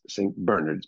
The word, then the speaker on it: St. Bernard's: My parents sent me here in Manhattan to a school called St. Bernard's, but it's pronounced St. Bernard's because it's British, and it was established and staffed mostly by British masters, teachers, when I went there.